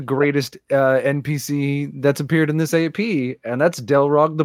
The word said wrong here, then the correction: Deldreg: Is it Deldreg? Did I mispronounce? – Deldreg. Yeah, that's how I pronounce it.